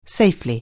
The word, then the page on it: safely: anglais